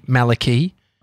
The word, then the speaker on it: malarkey: That chocolate at the party, Malarkey. – Oh, yeah, sure. – It's Malarkey. I just Googled the pronunciation.